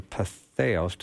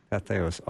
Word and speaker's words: Patheos: When we come back, I want to talk about another article you wrote in Patheos. Did I say that right this time? – Sure. – Patheos.